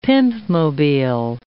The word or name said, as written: pimpmobile